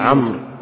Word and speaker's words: عمرو: عن ابن ماهك عن عبد الله بن عمرو قال تخلف عن النبي عمرو. هذه تنطق ولا تقرأ، تقرأ عمرو ولا تنطق.